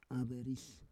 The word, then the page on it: averis: Roon